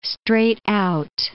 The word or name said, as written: straight-out